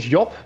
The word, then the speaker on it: Job: So the Dutch pronunciation is Job, but whatever like is most comfortable for you.